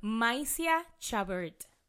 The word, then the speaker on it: Mysia Chabert: Mysia Chabert.